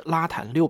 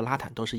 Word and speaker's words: RATAN: 一九六五年八月十八日，俄罗斯科学院射电天文望远镜工程开工建设，它的英文缩写是 RATAN 杠六零零，就是拉坦六百。后面呢，我就把它念成拉坦六百了啊，或者拉坦都是一样的。